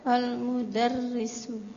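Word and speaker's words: Al-mudarrisu (المدرّس): Yang benar bacanya gimana? Ro-nya pakai tasydid. Al Al-mudarrisu.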